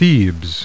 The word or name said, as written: Thebes